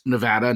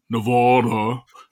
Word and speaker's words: Nevada: I was an attorney for one of the leading gaming companies in Nevada, which is pronounced Nevada, not Nevada. People in Nevada hate when they pronounce it wrong.